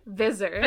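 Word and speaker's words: Visser: Vizzer. – Vizzer. – Like rhymes with scissor.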